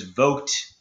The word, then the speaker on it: Vogt: But I did not know the history of it, and that they acquired those patents from a defunct brand known as Vogt, I think is how you pronounce it, is V as in Victor, O, G as in George, T as in Tom, that's the guy's name.